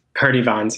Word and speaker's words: Purdie-Vaughns: She explicitly spoke against that, very similar to how you guys said that um, Valerie, is it Purdue Vaughn? Is that her? Purdie-Vaughns, yeah. But Purdie-Vaughns, yeah.